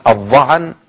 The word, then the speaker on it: ظعن: والظعن أي الرحلة من مكان إلى آخر، الظعن، وتنطق الظعن بسكون العين وكذلك بفتحها لغة أخرى وقراءة صحيحة متواترة أيضا أخرى، فتقول الظعن والظعني.